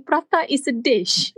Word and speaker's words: prata: So actually, P-R-A-T-A is pronounced as prata, which means to speak or to talk. – Oh, interesting. – I was like, oh, it's not, you don't say prata, you say prata.